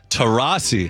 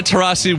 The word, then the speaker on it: Taurasi: Taurasi. – Who? – Diana Taurasi. Diana Taurasi? Taurasi or Taurasi? How do you say it? Diana. – No, it's a silent D.